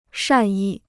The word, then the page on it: 善意: shàn yì